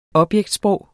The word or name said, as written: objektsprog